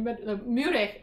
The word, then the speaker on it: Meurehg: He changed it to Meurehg because of the M-E-U-R-E-H-G. And people kept thinking we were German, but we're not. right? We're Mexican, but French Lebanese. So he changed that pronunciation a little bit.